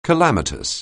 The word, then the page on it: calamitous: anglais